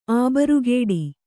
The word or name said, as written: ಅಬ್ರೂಗೇಡಿ